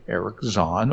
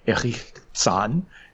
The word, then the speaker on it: Erich Zahn: Turns out that the garret is occupied by a fellow named Eric Zahn, or Erich Zahn, if we're pronouncing it in German.